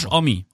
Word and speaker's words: Homme: Ja, Omi. Den habe ich. – Den ich komme mal durcheinander. Wie wird er denn eigentlich ausgesprochen? – Josh Omi. – Josh Omi. – Hier steht er.